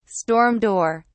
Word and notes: storm door: British English

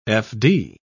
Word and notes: fd: British English

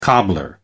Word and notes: cobbler: American English

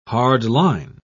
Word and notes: hardline: British English

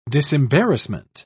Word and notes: disembarrassment: British English

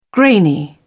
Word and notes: grainy: British English

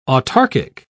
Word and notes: autarchic: British English

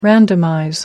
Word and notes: randomize: American English

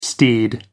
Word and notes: steed: American English

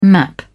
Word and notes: map: British English

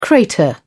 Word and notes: crater: British English

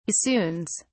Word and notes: bassoons: British English

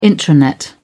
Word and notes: intranet: British English

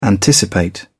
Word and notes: anticipate: British English

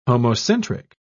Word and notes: homocentric: British English

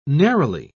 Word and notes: neroli: British English

